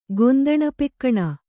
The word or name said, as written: ಗೊಂದಣಪೆಕ್ಕಣ